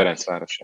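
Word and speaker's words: Ferencváros: I mean, your last club, you were in Ferencvaros. I don't know if I've probably butchered the name there. – Ferencvaros, yeah.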